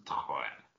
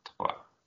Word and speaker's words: Troyes: Troyes. I think it's pronounced the same way as they say three. – Troyes.